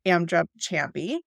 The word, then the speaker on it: Amdrup-Chamby: So, joining me today to talk about all of these things is expert in all things omega 3, Oliver Amdrup Chamby.